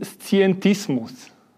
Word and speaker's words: Scientismus: politischen Scientismus. Habe ich das richtig ausgesprochen und was ist das? – Ja, das haben Sie richtig ausgesprochen, aber zunächst würde ich einmal sagen, ich bin Wissenschaftler in erster Linie und natürlich höre ich auch auf die Wissenschaft.